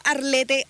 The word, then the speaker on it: Arlete: Guión bajo Arlete, Arlete Núñez. Me llamo Arlet, pero se escribe Arlete.